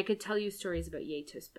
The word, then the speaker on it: gjetost: It's spelled like G-J-E-T-O-S-T. – Okay. – It's pronounced Yay Toast.